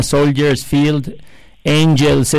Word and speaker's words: Soldiers Field Angels: Él es socio director de CIMA Corporate Finance y también es socio de un fondo de Venture Capital que se llama Soldiers Field Angels. Espero haberlo pronunciado correctamente, Alex. ¿Cómo estás? Buenos días. – Hola, buenos días. Sí, muy, bien pronunciado.